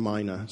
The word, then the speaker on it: mina: What's the proper pronunciation of this word? And we settled on mina.